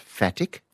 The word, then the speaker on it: phatic: P-H-A-T-I-C, pronounced fatic.